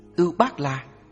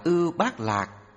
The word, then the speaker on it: Ưu Bát La: Ưu Bát La, phiên âm từ Phạn ngữ là Út Ba La, cũng đọc là Ưu Bát Lạc hay Ô Bát La, là một loại hoa sen quý màu xanh.